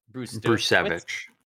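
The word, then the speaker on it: Brzustewicz: Sorry, how do you pronounce that? Hunter Brusevich. Brusevich. Yeah, the Z is silent.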